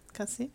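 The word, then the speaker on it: Kazé: Ich glaube, die werden Kase ausgesprochen. – Kase.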